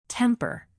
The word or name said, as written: temper